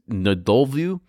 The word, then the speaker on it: Ndlovu: So I mentioned back on November 2018, Les and Paul Ansley, they launched their first bottle of gin called, I can't even say this right, but it's Ndolvu. It's spelled N-D-L-O-V-U. Ndolvu? I'm not going to try it again, but I just spelled it for you.